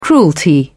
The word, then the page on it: cruelty: British English